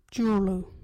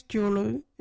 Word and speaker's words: Juurlu: Our local Wajiri word is Jurlu. What is Jurlu? Jurlu is salt. Jurlu. You can use Jurlu in this sentence. You got any Jurlu? Jurlu is spelled J-U-U-R-L-U.